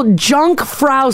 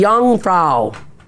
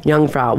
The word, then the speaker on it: Jungfrau: Oh, people say I'm pronouncing the J and it should be a Y, so it should be Jungfrau.